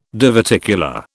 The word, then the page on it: diverticular: British English